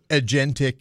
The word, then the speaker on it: agentic: Agentic.